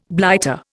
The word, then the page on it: blighter: Indian